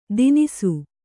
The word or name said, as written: ತಿನಿಸು